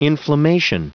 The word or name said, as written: inflammation